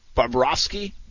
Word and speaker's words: Bobrovsky: Florida Panthers goalie, Sergei Bobrovsky. Gosh, did I say that right? – You sound confident. You're good. It sounds good to me.